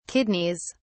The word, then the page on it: kidneys: British English